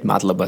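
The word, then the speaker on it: Мадлоба (მადლობა): в грузинском вряд ли можно сказать «мадлоба» там, да. – А какие, все-таки вообще, «мадлоба»? Да, какие... То есть ты просто ровно да. Отчеканиваешь три слога, да?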